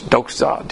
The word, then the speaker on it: doxa: D-O-X-Z-A when you pronounce it, but in the Greek, it's D-O-X-A, but you notice, for you purists out there, you notice that I throw a Z in there every once in a while.